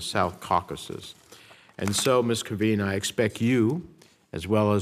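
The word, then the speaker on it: Kvien: I'll begin with Ms. Kavin. Is that the correct pronunciation? Kavin, okay, I'm sorry, who has been nominated to be the ambassador to Armenia.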